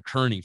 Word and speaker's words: Kearney: It's called Kearney. K E A R N E. – When you spell it out, it sounds like Kearney. E A makes that E. – Kearney. – Kearney.